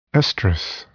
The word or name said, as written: estrous